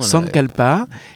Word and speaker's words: sankalpa: Et dans ce yoga nidra, bah, il y a des affirmations positives qu'on appelle sankalpa. Je ne garantis pas la prononciation. oui, elle dit ça exactement. – Dans sankalpa.